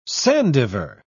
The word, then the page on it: sandiver: British English